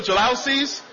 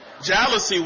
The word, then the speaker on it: jalousies: My wife and I, when we first got married, we had a 1,200 square foot apartment with those old jalousies. That's how you pronounce it, the jalousy windows.